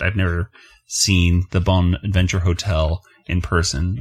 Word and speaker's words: Bonaventure: The Bonaventure. That's how you say it.